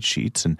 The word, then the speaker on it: Scheetz: And I said, Sheets. And she says, okay, how do you spell that? And I said, S-C-H-E-E-T-Z.